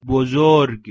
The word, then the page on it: buzurg: Persian; male